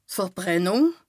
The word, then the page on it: Verbrennùng: Bas Rhin